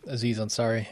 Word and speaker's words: Aziz Ansari: 7 Days of Hell, Master of None, the Netflix series, the Aziz Ansari. Is that how I pronounce his name? – Aziz Ansari, yeah.